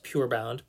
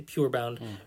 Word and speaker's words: Purebound: And then also, Andy Welfley of Erasable said he's a big fan of the, I don't know if this is pronounced Purebound, P-U-R-bound, but I say Purebound, Write Notepads notebook for vacations.